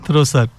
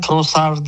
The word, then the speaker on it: Trossard: Ömer Bey diyor ki Trossard ismini yanlış telaffuz ediyorsunuz demiş. – Trossard diye biliyorum ben. – Trossard. – Fransa ile çok haşır neşir değil ama Trossard diye, yani D'yi okumuyorsun, onu yutuyorsun. Hı hı. Sonunda Trossard diye söyleniyor.